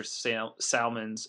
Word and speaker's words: Salmons: The Pelicans got Norris Cole and Sean Williams, and the Suns got Granger, Salmons, and Hamilton. Okay. Really nothing too interesting there beyond you getting Goran. – John Salmons, sir. I think it's a silent L.